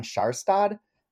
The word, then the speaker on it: Kjærstad: And he was very obsessed with the Seducer, the first book in the trilogy, which I may still be mispronouncing his name, but we were always told to say it's sort of like Jan Kjærstad. And anyone who's Norwegian can correct that as they want to do.